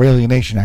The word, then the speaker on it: Alienation: There's 3 Days Grace off their latest CD, the title track called Alienation. Or Alienation, actually, putting the wrong emphasis on the wrong syllable there.